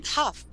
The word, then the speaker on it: Hough: Oh, Hough. You say Julianne Hough. She's beautiful. – Oh, okay. Is she a? – It's pronounced Hough. – Hough. Okay.